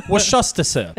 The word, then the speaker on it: Worcester: Worcester, sir.